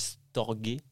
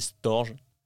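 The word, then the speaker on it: storgē: Alors là, je pense que c'est du grec, donc ça se dirait storgé ça s'écrit Storge, mais je pense pas qu'il disait Storge. Storgé.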